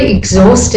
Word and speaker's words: exhaust: Now in the word exhaust and exhibit, you can see a consonant after the X, the H, but the H is silent, and so that means that after the X, really all you're hearing is the vowel. So the first word is exhaust.